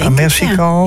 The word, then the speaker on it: Amersikal: Muziektheatergroep Amersikal, spreek je het zo, hè, denk je? – Amersikal, ja, speelt Echo's van Verzet.